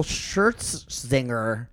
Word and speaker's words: Scherzinger: Nicole Scherzinger.